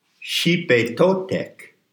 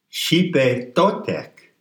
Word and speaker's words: Xipetotec: Xipetotec. It's spelled X-I-P-E because the X in transliteration of the Nahuatl is a S-H sound. Xipetotec.